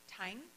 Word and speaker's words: Thanh: Like Pastor Barry said, I'm a friend of Thanh. I pronounce it the Northern Vietnamese way.